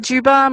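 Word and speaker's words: Dziuba: So they can just type in my name into Google, Sylvia Juba.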